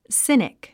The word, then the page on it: cynic: sínik; スィニック